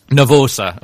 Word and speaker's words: nervosa: Nervosa.